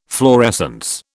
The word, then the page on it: fluorescence: British English